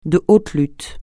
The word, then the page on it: de haute lutte: francais